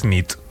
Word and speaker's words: nit: Y noche es nit. – Muy bien. – Buena nit. Buena nit, es verdad. – Buena nit.